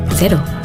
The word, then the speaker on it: cero: In New Zealand, zero alcohol Corona, cero, is pronounced cero.